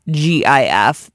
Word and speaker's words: GIF: Just so you know, the guy who created the GIF says it's pronounced GIF, as in the peanut butter.